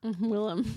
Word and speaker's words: Willem: William Dafoe. Willem? – Willem. – Willem. I said it right in my head and then my mouth changed it.